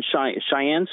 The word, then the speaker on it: Cheyenne: We're doing a picnic on the plaza that's going to be hosted on Cheyenne Street. Did I say that right? – Yep.